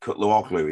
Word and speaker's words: Cutluoglu: I always struggle with Cutluoglu. Is that okay? – It's Cutluoglu, and I probably just said it wrong as well. So the G's silent and...